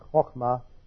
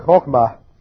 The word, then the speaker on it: chokmah: That's that Hebrew word chokmah. That's a good word to pronounce if you got a sore throat. It's that Hebrew word chokmah, which is used for wisdom in the Proverbs.